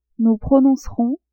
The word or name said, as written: nous prononcerons